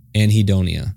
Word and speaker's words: anhedonia: anhedonia is how you pronounce it.